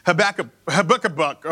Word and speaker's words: Habakkuk: But scholars say the way to pronounce it is Habakkuk or whatever.